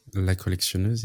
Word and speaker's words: La Collectionneuse: La Collectionneuse, yes.